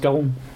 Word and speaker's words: Charon: Même ça se prononce Caron.